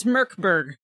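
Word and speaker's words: Mörk Borg: It's Merkborg. Borg. Merkborg. Because the Merkborg. – M-O-R with the umlaut is pronounced like the myrrh in murder.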